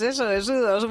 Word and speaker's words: U2: Se dice así, ¿no? – Eso es, U2.